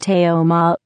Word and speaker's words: Teoma: Google's supposed over-reliance on popularity was one of the inspirations behind Teoma, pronounced Teoma, founded in 2000 by computer scientists Apostolos Garosolis and colleagues at Rutgers University in New Jersey.